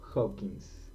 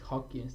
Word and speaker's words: Hawking: O sea, ¿es Hawking Hawking o Hawkins? Es Hawking, Stephen Hawking. Hawking.